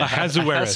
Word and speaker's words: Ahasuerus: Ahasuerus. Ahasuerus.